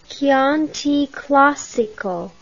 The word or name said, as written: Chianti Classico